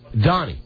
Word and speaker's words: Doni: Donnie. You spell it D-O-N-I. – Don? – D-O-N-I. – D-O-N-I? – Yeah, now, don't sign me up for anything.